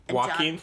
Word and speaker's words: Joaquin: And the two main people are still Iquan Phoenix. – Joaquin Phoenix? You say Iquan? He's fantastic. – I always say it wrong.